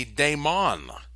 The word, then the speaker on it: daemon: Then we have, in the ancient Greek, we have the daemon. That's spelled D-A-E-M-O-N.